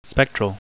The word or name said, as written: spectral